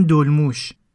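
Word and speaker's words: dolmuş: توی قسمت اول من دو تا کلمه رو اشتباه تلفظ کردم اون شهری که موبین و دوستاش میرن اونجا که یه کمپ هم داشته اسمش قازیانتپه همینطور به اون ونهای توی ترکیه هم میگن دلموش که من اشتباه میگفتم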